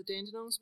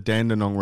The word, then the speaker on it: Dandenong: Yeah, if you want to pronounce it correctly as well, it's not the Dandenong Ranges, it's the Dandenong Ranges. That's how you pronounce that properly.